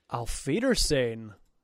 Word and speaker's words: Auf Wiedersehen: Auf Wiedersehen.